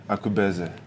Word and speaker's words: Akubeze: Yes, um, I am here at Caesar's with the lovely Akubeze.